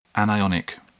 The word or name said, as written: anionic